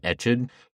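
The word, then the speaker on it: Ecsed: She was raised at the family castle in Esked, Hungary. How do you say that? I'm going to look it up so I don't sound like an asshole. So she was raised at the family castle in Eched, and in 1575, she married Count Nadazdi.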